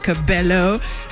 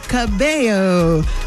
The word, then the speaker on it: Cabello: Camila Cabello, Lil Nas X, for those that were saying Cabello, how many times must I remind you? It is not pronounced Cabello, Cabello, like mayo.